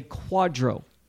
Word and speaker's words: QDRO: What you are receiving when you receive a breakup of a 401k is called a quadro. Q-D-R-O. It's pronounced quadro, but it stands for Qualified Domestic Relation Order.